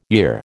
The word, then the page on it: year: American English